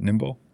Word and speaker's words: Nimble: Michael is a seasoned innovator and author with over 25 years in the SAP ecosystem, from co-founding Nimble, is that how you say it? Nimble? N-I-M-B-L? – That's right.